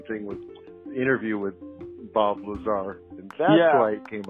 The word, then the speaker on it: Lazar: No, no, no, no. It's Lazar. It's pronounced Lazar.